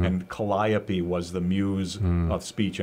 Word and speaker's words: Calliope: I have to commend you, for your pronunciation of Calliope.